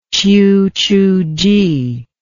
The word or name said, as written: qiuchuji